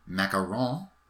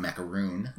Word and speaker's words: Macaron: And the Aqua Mickey Macaron, which is the French pronunciation. – Mm. – It's not macaroon.